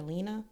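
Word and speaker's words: Leana: It's spelled like Leanna, but NPR kept calling her Lena. So I'm gonna do that too.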